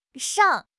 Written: shang3

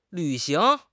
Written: 履行

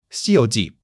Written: Xi1 you2 ji4

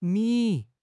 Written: Mi3

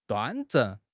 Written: 端正

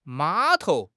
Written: 码头

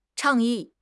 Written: 倡议

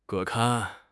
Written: 把关

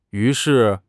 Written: yu2 shi4

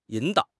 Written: 引导

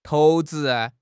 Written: 投掷

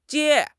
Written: jie1

